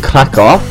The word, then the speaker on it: Krakow: So, yes, I was in Krakow. That's C. No, it isn't C. Oh. K-R-A-K-O-W. And the W is pronounced as a V.